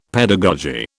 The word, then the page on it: pedagogy: British English